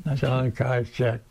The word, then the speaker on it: Chiang Kai-shek: And then the communists came in, and we got out of there along with the how do you pronounce it? – Chaigon? – Chiang Kai-shek. – There you go.